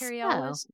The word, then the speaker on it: Cary Elwes: played by Cary Elwes. Is that how you say his name? – I think Cary Elwes. Yeah, from Princess Bride.